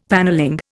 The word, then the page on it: panelling: Indian